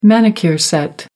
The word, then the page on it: manicure set: American English